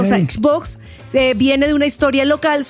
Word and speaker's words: Xbox: Oigan, pero sí se pronuncia Xbox. Xbox. Ahí dice.